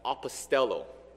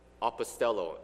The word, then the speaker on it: apostello: And the Greek word is pronounced as apostello, apostello. And if you listen to this word carefully, then you should remember that this word sounds very close to the word apostles.